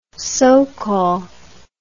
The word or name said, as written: sokol